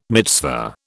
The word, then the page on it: mitzvah: British English